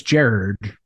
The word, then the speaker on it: Gerard: I think it's Gerard. Like it's Gerald, but with an R instead of an L. – Yeah, Gerard. – Yeah. – He's like, oh, it's Jared Butler.